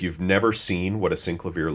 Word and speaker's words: Synclavier: At least that's how Henry pronounces it, the synclavier. – Hey, wait, is it synclavier or the synclavier? – It's synclavier. Stop trying to get street cred.